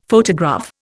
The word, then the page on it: photograph: Indian